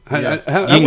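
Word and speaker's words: Yuengling: One of my favorite beers to order is a, and I'm not going to say it right now, is a Yingling. – Yingling.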